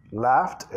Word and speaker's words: laughed: I've loved, I've laughed, laughed, E de pronunciada como T.